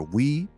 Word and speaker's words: Oui: Like a smooth song a strut, like that one Jeremiah song, O U I or We, pronounced we in French.